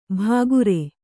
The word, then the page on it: ಭಾಗುರೆ: Kannada